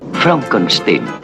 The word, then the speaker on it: Frankenstein: Frankenstein. Se pronuncia Frankenstein.